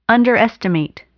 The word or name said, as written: underestimate